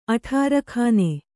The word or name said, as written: ಅಠಾರಖಾನೆ